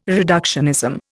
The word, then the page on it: reductionism: Indian